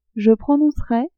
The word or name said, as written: je prononcerai